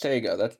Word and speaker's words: Sega: So, number one, played any of the original three Sonic the Hedgehog games on Saga Genesis? – No, you haven't. – Correct. What's a Saga Genesis? – It's pronounced Sega.